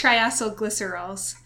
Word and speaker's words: triacylglycerols: Hold on. This is triacylglycerols. – Wait, can I try? – Sure. – Yes. I think it's triacylglycerols. – Ooh, that sounds way better.